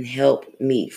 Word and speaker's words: help meet: But for Adam, there was not found an help meet for him. How do you say I was supposed to say it? – Help meet. – Help me. Like, help me.